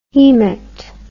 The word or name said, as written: hemat